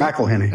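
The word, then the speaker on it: McIlhenny: And you mentioned this name, Edmund McElhenney. Is that how we pronounce it? – McElhenney.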